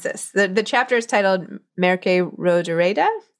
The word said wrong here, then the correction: Mercè Rodoreda: Is that right? Is that sound right? Merce, Merce Mercè Rodoreda.